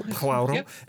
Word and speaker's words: Poirot: I think I'm saying that correctly. I always get that screwed up. And Poirot.